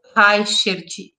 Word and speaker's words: Reichert: O convidado deste episódio é Armin Daniel Reichert.